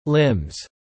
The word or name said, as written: limbs